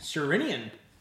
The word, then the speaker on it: Sherinian: Sherinian. – Derek Sherinian. – Sherinian, yeah.